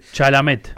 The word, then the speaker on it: Chalamet: Chamblet, algo así se pronuncia. – Pero sí. Muy famoso. – Es muy famoso, es como el actor de... – Chalamet. – Ahí está, Chalamet.